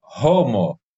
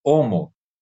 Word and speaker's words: Homo: Então é por isso que se você falar o nome científico da espécie humana nos Estados Unidos, provavelmente o pessoal lá falará Homo, porque o H tem pronúncia em inglês, né? Aí fica Homo sapiens, né? E nós não, o H não tem pronúncia para gente, então não faz sentido esse H ter som, né? O mais correto é nós falarmos Homo, né? E ignorar a pronúncia do H, porque tem esses regionalismos que são absolutamente corretos.